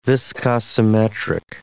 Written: viscosimetric

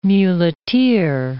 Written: muleteer

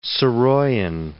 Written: Saroyan

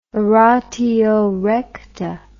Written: oratio recta

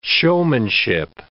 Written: showmanship

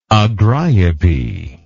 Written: agriope

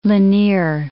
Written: Lanier

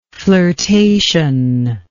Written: flirtationless

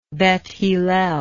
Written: Beth Hillel

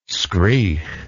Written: skreegh

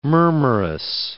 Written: murmurous

